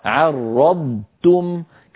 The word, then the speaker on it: عرضتم: علينا أن ننتبه ألا تتحول الضاد إلى طاء وأيضا التاء التي بعد الضاد تتحول إلى طاء فينطق بعض إخواننا عرضتم أو عرضتم يعني يحول الضاد إلى طاء لابد أن تبقى الضاد ضاد والتاء تاء هكذا عرضتم